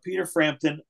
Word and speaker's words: Frampton: His name rhymes with Schmieder Shrampton. – That would be correct. Peter Frampton,